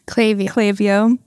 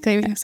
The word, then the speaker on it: Klaviyo: I never know how to say it. – Klaviyo. Well, I say Klaviyo. – So okay. Klaviyo. I think you're right.